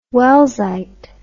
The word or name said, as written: wellsite